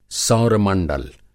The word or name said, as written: सौर मंडल